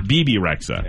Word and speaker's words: Bebe Rexha: Bebe Rexha.